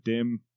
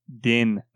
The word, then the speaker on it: Din: No, we've got the Jin and the other Jin, and I'm trying to find anything else that rhymes with Dim. – Din, not Dim.